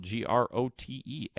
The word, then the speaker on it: Grote: If you want to do it on Twitter, at Mark Grote Sports is the way to do it. My last name is spelled G R O T E at Mark Grote Sports on Twitter.